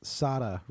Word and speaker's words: Sada: the actor's name is pronounced Sada Ramirez. It's Sada. I listened to an interview with Michael Patrick King, and he constantly refers to Sada Ramirez. – Sada. – So it's Sada.